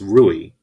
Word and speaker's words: Rui: So, by the way, I'm pronouncing this Rui and with an R. But you sound like you're pronouncing it like Louis the 14th.